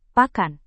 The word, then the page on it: pakan: Indonesian